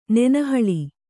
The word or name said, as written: ನೆನಹಳಿ